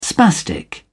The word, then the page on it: spastic: British English